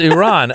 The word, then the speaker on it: Iran: It's Iran. I always say, like, imagine you, just the letter E and then a guy named Ron. – Oh. – Iran.